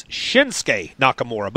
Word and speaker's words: Shinsuke Nakamura: It's Shinsuke Nakamura.